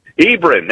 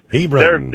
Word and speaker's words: Hebron: How do you say that out there? – Hebron. – That's it. – Hebron.